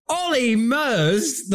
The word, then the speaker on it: Ollie Murs: And Ollie Murs. – Ollie Murs, the man whose name rhymes with polymers.